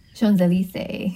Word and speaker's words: Champs-Élysées: So the work premiered on May 29th in 1913 at the Theatre de Champs-Élysées in Paris. I'm not sure if I'm saying that properly. – Champs-Élysées.